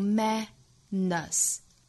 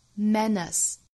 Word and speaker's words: menace: 单词里只要不含有字母 R 是不可能卷舌的，不管音还是美，所以这个词的音和美是相同发音的，都读 menace, menace, 第二个音节是 schwa sound, menace, success is a menace, success is a menace。